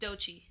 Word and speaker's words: Doechii: But Dochi is spelled D-O-E-C-H-I-I. Like Do and then Chi.